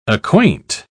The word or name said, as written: acquaint